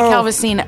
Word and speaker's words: Calvasina: Did I say your name right? – So close. No. It's Holly Calvasina.